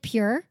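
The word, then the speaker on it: Pjur: I really like Pure. – Yeah, Pure's awesome, too. – Pure's a great lube. – It's P-J-U-R.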